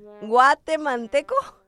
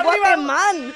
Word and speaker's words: Guatemalteco: ¿Guatemalteco? – No. No sé. – How do I say it? – Guatemalteco. – Guatemalteco. – ¡There it is! – This whole time I thought it was Guateman.